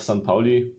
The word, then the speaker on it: St. Pauli: wäre ich auch gerne mal wieder tatsächlich auf St. Pauli, oder? Habe ich es richtig gesagt? – Ja. – Am Millern-Tor ja.